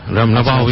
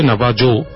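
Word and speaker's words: Navajo: নাবাহোরা, নাবাহো ইন্ডিয়ানসরা, যদি লেখায় আসে নাবাজো, বাট অ্যাকচুয়ালি প্রোনাউন্স হচ্ছে নাবাহো।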